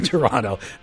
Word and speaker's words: Toronto: That's Toronto. Unless you're from Toronto, and then it's Toronto. They don't have a T if you're from Toronto. They pronounce it differently.